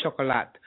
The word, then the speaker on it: chocolat: And personally, what I think she's phonetically spelled the way you say chocolate in French. So it's chocolat.